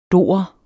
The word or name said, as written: dorer